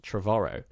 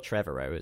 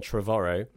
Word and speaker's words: Trevorrow: The bad director of the previous one, Colin, I learned that his surname is pronounced Trevorrow. – Trevorrow. – And it's supposed to rhyme with, or like, it's got the same kind of intonation as tomorrow. So not Trevorrow, as I'm sure most people say, but Colin Trevorrow.